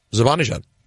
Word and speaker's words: Zibanejad: So that's how you say his name. Zibanejad.